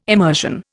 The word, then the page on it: emersion: Indian